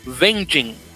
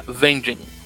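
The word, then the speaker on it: Weglzyn: El caso es que se pronuncia correctamente como venging Y descubrí, César, que ese apellido es un apellido polaco hay una ciudad en Polonia bien pequeñita con ese nombre y se pronuncia Venjin.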